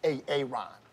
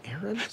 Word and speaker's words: Aaron: Know, I said it like four times, so why didn't you say it the first time I said Aaron? Because it's pronounced Aaron. Son of. You done messed up, A.A. Ron.